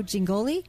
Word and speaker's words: Jingoli: Jack Morris and Joe Jingoli? – Yes.